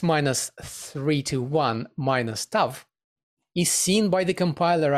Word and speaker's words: Tav: It's a Hebrew character used for parameter name, and it's called Tav. It's pronounced as voiceless T.